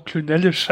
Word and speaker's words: Clynelish: Fortan soll er nur noch Klünellisch ausgesprochen werden. Das klingt super. Doch, Klünellisch. – Ja, der wird komisch. Also der wird C-L-Y-N-E-L-I-S-H.